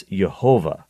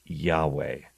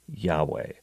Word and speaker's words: Yahweh: And so in the old days, people used to pronounce this name as Yehovah or Jehovah. And that's where we get the name Jehovah from in the Old Testament. But now, these days, in these times, people and scholars tend to say, no, it actually is probably pronounced Yahweh, Yahweh.